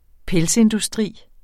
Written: pelsindustri

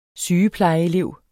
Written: sygeplejeelev

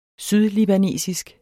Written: sydlibanesisk

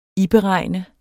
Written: iberegnet

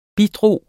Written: bidrag

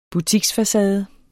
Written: butiksfacade

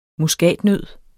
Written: muskatnød

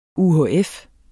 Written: UHF

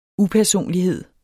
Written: upersonlighed

